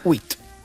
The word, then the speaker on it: huit: Huit.